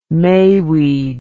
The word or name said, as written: mayweed